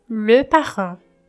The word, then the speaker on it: le parent: E um dos pais, quando não importa qual deles, é le parent. Você vai saber se é plural ou se é singular observando a pronúncia do artigo le, nesse caso. Le parent, singular. Les parents, plural.